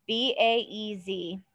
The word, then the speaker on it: Baez: Spell it. B A E Z. Okay, sounds good. Baez.